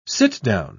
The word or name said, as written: sit-down